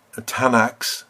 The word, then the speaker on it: Tanakh: pronounced Tanakh, very soon became a world book.